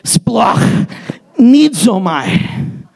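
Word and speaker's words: splagchnizomai: Let me pronounce it for you. Ready? Splach nidzomai.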